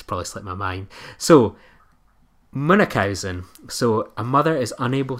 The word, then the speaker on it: Munchausen: Well, I think the correct pronunciation is Munchausen.